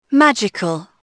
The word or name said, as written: magical